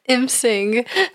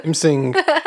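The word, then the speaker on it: M'sing: Sure, sounds like in sync. – Tell me why. – Nice. Imsing. – Imsing, which sometimes took the form of a deer-like creature with leathery wings.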